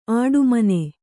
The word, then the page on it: ಆಡುಮನೆ: āḍumane